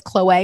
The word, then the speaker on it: Khloe: That Khloe is, by the way, that is how you say her name. She like, on the reality TV show by Bravo Andy, they were interviewing her and he says Chloe and she's like, thank you. That's the correct way to say my name.